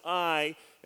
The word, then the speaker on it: Ai: Some of you might say Ai, but it's pronounced Ai.